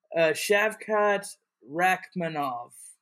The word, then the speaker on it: Shavkat Rakhmonov: Shavkat Rakhmonov.